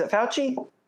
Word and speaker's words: Fauci: It's Fauci.